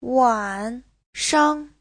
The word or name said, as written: wan3shang5